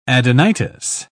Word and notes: adenitis: British English

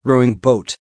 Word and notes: rowing boat: American English

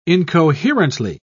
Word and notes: incoherently: British English

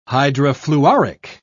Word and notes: hydrofluoric: British English

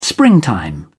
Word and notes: springtime: British English

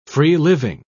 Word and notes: free-living: British English

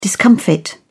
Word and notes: discomfit: British English

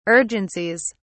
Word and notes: urgencies: British English

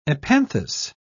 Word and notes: epanthous: British English

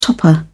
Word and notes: topper: British English

